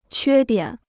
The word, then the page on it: 缺点: quē diǎn